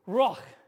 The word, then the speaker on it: ruach: And the word is R-U-A-C-H. Ruach. Which sounds like you're trying to clear your throat.